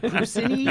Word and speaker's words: Puccini: Puccini? – Yeah.